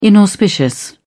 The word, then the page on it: inauspicious: British English